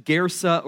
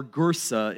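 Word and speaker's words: Kursi: probably in the ancient town of Cursi, which is pronounced Gersa or Gursa in the local dialect.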